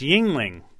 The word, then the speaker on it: Yuengling: All right, and our Pennsylvania callers are calling in saying it's Yuengling.